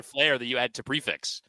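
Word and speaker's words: prix fixe: But we didn't realize that for Valentine's Day, I'm going to stop short of pronouncing this because I know you say it the proper way that annoys everybody, but I'm going to stop short and let you actually say it. – Prefix? – Yes.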